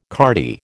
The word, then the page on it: cardie: American English